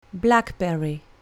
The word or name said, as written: blackberry